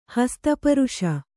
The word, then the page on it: ಹಸ್ತಪರುಷ: Kannada